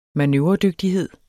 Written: manøvredygtighed

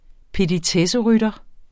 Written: petitesserytter

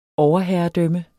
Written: overherredømme

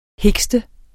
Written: hikste

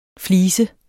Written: FLISERNES

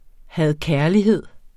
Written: had-kærlighed

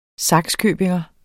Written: sakskøbingenser

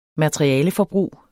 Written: materialeforbrug

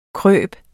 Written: krybe

